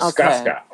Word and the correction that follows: Skaka: Oh, no, actually, Skaka. Sorry, no second S. Let me spell it the right way. S-K-A-K-A.